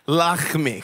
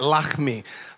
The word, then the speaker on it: Lachmi: Giant number two is a guy by the name of Lachmi. Lachmi. Okay, we're gonna say it like that. Lachmi.